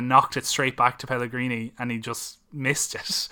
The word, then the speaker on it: Pellegrini: And I think relying on set plays and your kind of big players to kind of chip one in the way that Pellegrino, is that how you say his name? – Pellegrini.